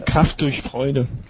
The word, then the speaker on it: Kraft durch Freude: It was Dirche. How do you say that in German? Dirche? – Kraft durch Freude. – Yeah, I think so. That's right.